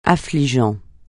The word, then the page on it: affligeant: French